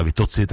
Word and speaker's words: Vitozzi: Tak ta se píše také Vito a dvě Z, že jo, tak taky neříkáme Vitozzi, ale Vitozzi, tak jak se to vyslovuje italsky.